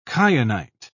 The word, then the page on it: kyanite: British English